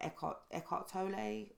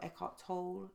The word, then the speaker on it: Eckhart Tolle: So I've been doing a lot of reading about Eckhart Tolle, or Eckhart Tolle, depending on how you want to pronounce his name.